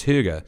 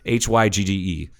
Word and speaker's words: hygge: It's Hygge. And that's Hygge. And yes, I did Google that while I was in the bathroom just now on break to make sure that I was pronouncing it correctly.